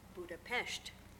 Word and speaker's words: Budapest: So in Hungarian, the S is a sh. So if you're in Hungary, it's Budapest.